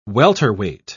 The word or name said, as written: welterweight